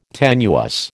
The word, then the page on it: tenuous: American English